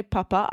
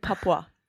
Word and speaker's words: Papua: So good, Papua. – Yes, Papua. Yeah, that's the correct pronunciation.